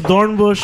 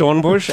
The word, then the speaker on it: Dornbusch: I'm Horst Dornbusch. – I'm Horst Dornbusch, and it's spelled D-O-R-N-B-U-S-C-H.